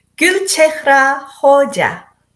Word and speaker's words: Gulchehra Hoja: Gulchehra Hoja.